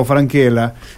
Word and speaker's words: Francella: El señor Guillermo Francella, o Franquela, como dijo que se pronuncia, su nombre generó enorme curiosidad, pero es un hombre conocido en el ámbito universitario porque fue decano en la UNR.